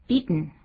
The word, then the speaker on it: beaten: 好，我们看解说部分 beat 这个及物动词表示击败的时候啊，它的三态是 beat beat beaten，最后那个念的时候变成 beaten，应该怎么念呢？ – beaten beaten 有等音鼻音出现。